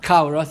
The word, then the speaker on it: cholera: If you Google it, I believe it's 1840s, where there's, I don't even pronounce this disease, choleria, right? How do you pronounce that in English? How is it? – Cholera, thank you.